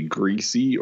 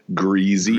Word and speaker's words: greasy: I don't know if I just sweat or my hair is particularly greasy or as it's pronounced here in the South, greasy. – Greasy. Yeah, there's a Z.